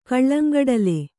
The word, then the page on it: ಕಲ್ಲಂಗಡಲೆ: kallaŋgaḍale